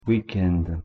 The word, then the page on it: week-end: French